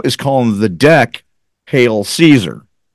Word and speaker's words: Caesar: Someone told me that it's actually pronounced Kaiser. I don't know if that's true. – No, it's Caesar.